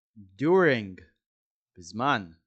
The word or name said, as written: during